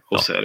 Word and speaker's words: Auxerre: Auxerre, hur fan, hur fan mm. uttalar man det? – Auxerre, ja.